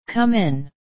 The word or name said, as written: come in